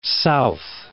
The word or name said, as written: south